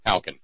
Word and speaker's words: Halcyon: Halcyon. Yeah. Rhymes with Falcon.